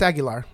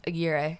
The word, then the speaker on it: Aguirre: They just go, oh, that's actually A-G-U-I even. If you just read the first like four letters, you're already going, oh, it's Aguilar. Like, nope. If you read it, there's no L.